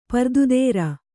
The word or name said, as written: ಪ್ರತಿದೋರು